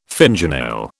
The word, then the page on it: fingernail: British English